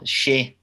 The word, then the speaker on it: Che Adams: It's like what I've said, until we made that pass for Che Adams, or Shea Adams, as I've been apparently saying his name wrong for all these years, Shea Adams. – It's not, I thought it was Che as well. – No, Shea.